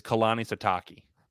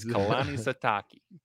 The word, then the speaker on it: Kalani Sitake: Coaches, pronounce his name for me. Katina Sitake. – I'm blanking right now. His name is Kalani Sitake. – Said Katina. – Katina, boy, is Kalani Sitake. – Kalani Sitake.